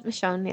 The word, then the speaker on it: Michonne: Michonne? I never know how to pronounce her name. – Yeah, that's Michonne, yeah.